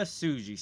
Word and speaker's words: Tsuji: You don't pronounce the T, and it's just the Tsuji. – Right. Yoda Tsuji.